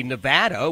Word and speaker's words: Nevada: It is clear to all that you and I both lived in the Silver State because we say Nevada, which is actually how it's pronounced.